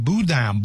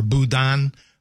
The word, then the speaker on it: boudin: Now, that's for those of you who are not familiar with the Cajun classic boudin or boudin, there's a couple of different ways. I mean, we pronounce it boudin or boudin like D-E-H-N.